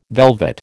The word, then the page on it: velvet: American English